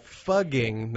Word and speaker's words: fugging: Yeah, fugging, not CK. – So rhymes with pug. – Yeah, exactly. Like bugging with an F.